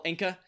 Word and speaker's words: Anka: So the panelists' guesses were Al Roker, Ringo Starr, and Paul Anka. That's how you pronounce it, right? – Yeah.